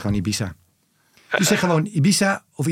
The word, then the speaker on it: Ibiza: De officiële Spaanse uitspraak, en dat komt dus uit Madrid, is Ibiza.